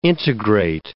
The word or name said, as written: integrate